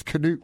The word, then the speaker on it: Knut: And my great-grandfather, Knud, K-N-U-D. – Yeah. – You know Knut Rockne? – Sure. – That's wrong. It's Knut Rockne. – Knut. – And I have an uncle, Knut.